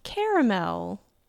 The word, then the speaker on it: caramel: So how do you pronounce caramel? With three syllables.